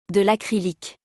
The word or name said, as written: De lacrylique